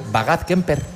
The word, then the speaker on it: Bagad Kemper: Tenéis al Bagad Kemper, sí.